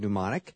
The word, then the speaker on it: mnemonic: I, I have to say, your memory's good, and I don't know if you use any devices like a uh, mnemonic device. Of course, the M is silent in the word mnemonic.